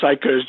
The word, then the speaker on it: PSICOS: And you came up with this term, or somebody came up with this term, PSICOS, S-I-C-O-S. Am I pronouncing that right? – The acronym is PSICOS, just so people could remember it.